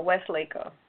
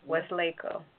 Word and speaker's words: Weslaco: No, West Laco. WESLACO. Some other little city I've never heard of. – WES? – That's how you spell it. WESLACO. West Laco.